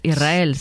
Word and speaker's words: Israel: Y la S con la R es difícil de pronunciar. La gente dice Israel, es sin verdad. Que suene la S. Israel, sí, sí, es difícil de pronunciar.